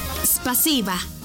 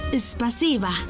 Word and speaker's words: Спасибо: Спасибо. Una vez más, Спасибо. Y se escribe Спасибо. S-P-A-S-I-B-A. Спасибо.